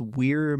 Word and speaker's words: Weir: There is a physician named Silas Weir Mitchell. Would you pronounce W-E-I-R? Is that Weir?